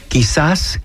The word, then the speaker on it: Quizás: Quizás.